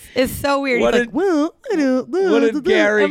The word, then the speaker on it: Gerry: So then we get brought out Jerry, or it's Gary. – It's Gary. – It's spelled G-E-R-R-Y, but it is Gary.